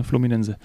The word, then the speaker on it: Fluminense: Und ähm, kurz noch weiter, der 34-jährige Künstlername Marcelo kehrt in seine Geburtsstadt Rio de Janeiro zurück, zu seinem Herzens- und Ausbildungsclub Fluminense. Fluminense, hört sich irgendwie spricht man so aus, ne? Fluminense.